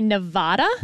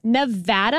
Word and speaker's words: Nevada: Because a lot of politicians would say Nevada. – Wrong. – And that is wrong. – Wrong. It is Nevada. And I had to train myself to say Nevapple so I could pronounce the A right.